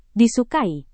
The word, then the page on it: disukai: Indonesian